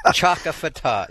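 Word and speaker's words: Chaka Fattah: Now, he's Congressman, help me to say it right, Chakafata? – No, well, let's Chakafata. – Chakafata? – Chakafata, yes.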